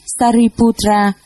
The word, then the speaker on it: Sariputra: Ngài Xá Lợi Phất, Ấn Độ đọc là Sariputra, chữ Hán là Thân Tử.